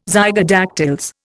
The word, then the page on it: zygodactyls: Indian